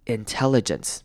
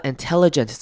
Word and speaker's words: intelligence: 好，那后面的 ence 呢，这边你也可以念成短母音 i 的声音，虽然它写的是 e， intelligence，或者是你可以念成轻音 intelligence，两种声音都是可以的。